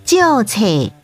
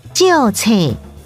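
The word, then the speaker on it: 借冊: 在图书馆借书要在期限内归还，借书台语念作旧册，旧册。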